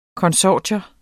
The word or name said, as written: konsortium